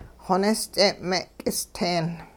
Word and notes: xonist'e'-me'k'isteen: hupa

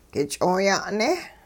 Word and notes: k'ich'ooya'ne': hupa

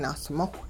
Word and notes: nahsmowh: Hupa

